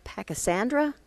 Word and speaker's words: Pachysandra: We've had Pachysandra, am I saying that right? – Yes, okay.